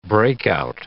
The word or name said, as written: breakout